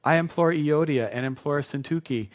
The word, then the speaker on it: Syntyche: That's how you pronounce it. It doesn't look like it. I feel so silly saying it correctly. Syntyche. Doesn't look like it should say Syntyche, but that's what it is.